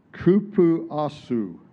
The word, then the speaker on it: cupuaçu: It's pronounced this way. Cupuaçu. It's spelled C-U-P-U-A-C-U-C, but pronounced cupuaçu.